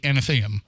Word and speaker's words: Athenaeum: He was courting somebody toward the end of his life uh, and he would visit her at the Athenaeum. And so I think I'm saying that right, right? – Yeah. So and he would visit her there, but he wasn't living in Providence.